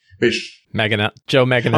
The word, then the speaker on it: Joe Manganiello: They've got Joe Mangani. I can never say his name. I always mangle his name. – Joe Manganiello.